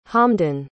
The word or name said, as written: hamdun